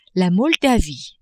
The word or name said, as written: Moldavie